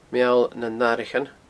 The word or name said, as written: meallnannathraichean